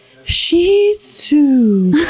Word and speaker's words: Shih Tzu: So I listened to it, and I found this very breathy woman pronouncing it. And she said, Shih Tzu.